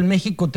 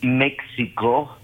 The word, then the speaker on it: México: Lo que recomienda la RAE ahí es que jamás se puede pronunciar México como si fuera una la otra X, el otro sonido de la X.